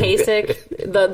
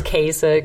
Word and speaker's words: Kasich: I'm going to correct you guys now. Love it was too. Kasich. The Republican nominee or possible nominee is Kasich. – We butchered that. That's okay. That's okay. Cashy.